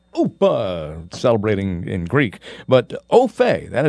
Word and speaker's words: au fait: It is au fait, pronounced au fait, but spelled A-U-F-A-I-T.